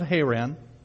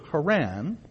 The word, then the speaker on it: Haran: Now you may notice, just as an aside, but kind of an important aside, that I pronounced the son of Terah's name Haran differently from the town in which Terah died, Haran. And that's because they're spelled very slightly differently in Hebrew, though almost identical.